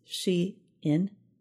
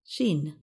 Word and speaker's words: Shein: Online fast fashion business Shein is Shein, not Sheen.